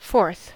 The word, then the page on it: fourth: [fɔɹθ]; /ˈfɔːθ/; /foəɹθ/; /fɔːɹθ/